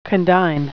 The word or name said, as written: condign